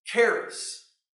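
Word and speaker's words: charis (χάρις): First off, the word grace in the Greek is a word that we pronounce as, as charis. Charis. That is what grace is in Greek.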